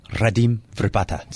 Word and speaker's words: Radim Vrbata: Radim Vrbata.